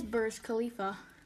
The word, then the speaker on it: Burj Khalifa: It is Burj Khalifa.